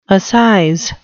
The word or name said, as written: assize